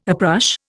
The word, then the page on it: uprush: Indian